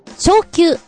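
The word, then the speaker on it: 蹴球: こちらのタイトルは、サッカーは、蹴る球、昇級と読むんですけど、など、スポーツ競技名の漢字表記一覧という風になっていて、ダダンと出てます。今のも全部含まれております。サッカーは昇級。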